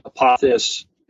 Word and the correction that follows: Apophis: And he says, many people make this mistake, but it's actually Apophis.